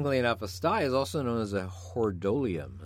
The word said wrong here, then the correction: hordeolum: Hordeolium. – I don't know if you knew that. Or hordeolum, actually.